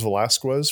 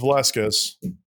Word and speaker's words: Velasquez: Did we have any other team that pronounced a guy's last name as Velasquez for 3 years? Because apparently that's exactly what the player told us. And then all of a sudden it was like, oh, nope, whoops. It's Velasquez. Just like we all thought it was. – Yeah. – Because that's how that name's pronounced. And that's how that name's always been pronounced.